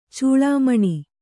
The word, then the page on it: ಚೇಳುಮಣಿ: cēḷu maṇi